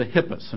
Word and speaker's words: Hippios: This race was called the Hippias, and it's spelled H-I-P-P-I-O-S.